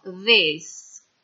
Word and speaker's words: this: Questo, questa al singolare suonano this. Al plurale, questi, queste diventano these. Quindi, quella I corta del singolare diventa invece una I lunga del plurale. This and these.